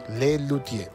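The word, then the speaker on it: Les Luthiers: Lelutier. Se escribe Les Lutiers con TH.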